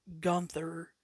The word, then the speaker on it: Gunther: There are people that have already started pronouncing Gunther, which is how it's pronounced in German.